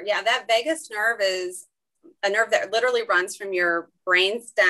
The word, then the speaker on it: vagus: And one thing I read in that book was that the gut and the brain are connected through the vagus nerve.